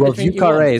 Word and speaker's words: Vieux Carré: Do you get Vieux Carré is how they say it in New Orleans, right? So, Blended. But that does not mean anything. That does not mean it's the correct pronunciation in French.